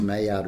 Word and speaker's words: Maillard: It's more the enzymes in the garlic itself, and it's actually more like they call it a Maillard, which is spelt with an L but pronounced Maillard reaction, where the garlic itself becomes black.